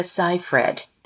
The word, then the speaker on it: Seyfried: Remember, when you get on ABC Live and millions of people are watching, it's Amanda Seyfried. – Seyfried. – Write it on your hand. – Yeah, I'm like, Seyfried.